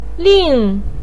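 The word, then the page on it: ling4: pinyin; tone 4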